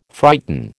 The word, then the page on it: frighten: American English